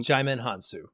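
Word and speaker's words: Djimon Hounsou: Help me out with the pronunciation. Is it Jimon Unsu or something like that, I believe? Who's in? – Jimon Hansu. Jimon Hansu. Yeah.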